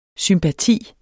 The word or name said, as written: SYMPATIS